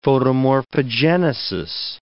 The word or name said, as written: photomorphogenesis